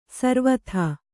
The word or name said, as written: ಸರವತ್ತು